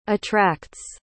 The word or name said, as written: attracts